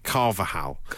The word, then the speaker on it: Carvalhal: Carvajal. – How's it spelled? – Carvalhal. But I think the middle L is silent. Carvajal.